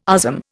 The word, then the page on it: USM: Indian